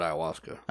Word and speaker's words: ayahuasca: Is it ayahuasca or ayahuasca? How do you say that? – I've always said ayahuasca.